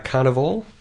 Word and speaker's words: Carnivorl: The next track is called, if I say this right, Carnival. – That's right. – Yeah. Why the spelling being C-A-R-N-I-V-O-R-L?